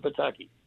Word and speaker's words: Pataki: Rhymes with shiitake. – Exactly. Just look at three-term Pataki.